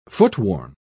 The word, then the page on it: footworn: British English